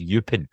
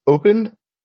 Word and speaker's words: Eupen: So yeah, we're just gonna go with I think it's Eupen. Almost like open, but with a little more to open.